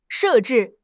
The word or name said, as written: 设置